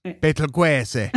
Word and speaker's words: Betelgeuse: Betelgeuse.